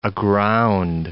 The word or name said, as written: aground